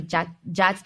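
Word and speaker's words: Jhats: Wait, it's really hard to pronounce. So it's called J-H-A-T, Jats, and then the women's are like Jatas or Jata.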